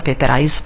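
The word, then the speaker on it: Paperize: Scritto paper come carta, I-Z-E, per cui si pronuncia Paper Eyes.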